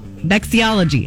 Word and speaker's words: vexillology: On The Big Bang Theory, Sheldon Cooper creates a podcast to teach people about vexillology. Is that how you say it, Jeff? Yep.